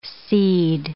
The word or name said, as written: cede